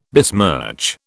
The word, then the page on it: besmirch: British English